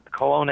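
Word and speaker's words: colón: It's the colon. Is that how you pronounce it? – I'm sorry. Say that again. – The local currency. It's fairly stable. – Yes, the colone is relatively stable.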